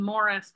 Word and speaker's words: Maurice: Okay, you say his name Morris, but you spell it Maurice.